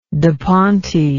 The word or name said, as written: da ponte